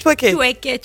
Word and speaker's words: chouaké: Nous y allons avec notre invité Christy Diamond, merci d'être avec nous, on vient d'entendre l'un de vos titres, s'appelle Checké, Checké, c'est une chanson d'ambiance, c'est bien cela? – C'est bien cela. – Je l'ai bien prononcé? Chouaké, chouaké. – Chouaké, chouaké.